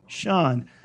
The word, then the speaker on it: Sean: Sean. – How do you pronounce this? – Seamus. – So where does that come from? Why is this also in this S sound making a shh?